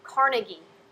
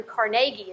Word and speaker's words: Carnegie: You know, another example, people pronounce his name Carnegie, but Andrew Carnegie is how his name is actually pronounced.